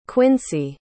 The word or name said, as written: quincey